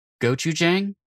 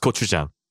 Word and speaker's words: gochujang: And I'm totally fine if I don't, but I'm going to say gochujang. – How do I? How did I just hear that? – It's pronounced gochujang.